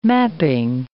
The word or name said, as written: mapping